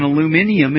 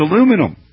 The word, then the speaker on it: aluminum: We love our British listeners and viewers, but you're wrong on aluminium. It's aluminum.